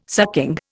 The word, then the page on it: sucking: Indian